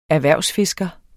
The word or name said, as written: erhvervsfisker